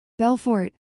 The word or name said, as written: Belfort